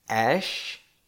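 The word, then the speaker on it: Æ: It's pronounced X, Ash, the A-E is pronounced as Ash, A-12, Musk.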